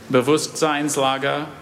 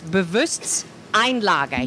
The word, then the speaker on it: Bewusstseinslage: It's Bewusstseinslage. He's saying Bewusstseinslage. Seinslager.